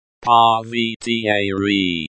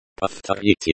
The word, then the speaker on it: Повторите: Повторите. Is pronounced like the, o, in, go. The, ye, at the end of the word is pronounced like the, e, in, the.